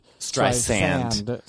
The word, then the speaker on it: Streisand: She likes it to be said Streisand. – She wants the S being pronounced.